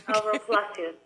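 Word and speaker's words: Palacios: That grew were growers and the generational aspects and one of the growers that you had mentioned was Alvaro, is it Palacio? Is that how you say his name? How do you? – Yes, Alvaro Palacio. Yes.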